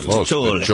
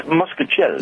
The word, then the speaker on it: mostaccioli: But that's the Italian pronunciation, mostaccioli. It's mostaccioli. – It's muscaccioli. – It's not muscaccioli. It's mostaccioli. That's the actual pronunciation.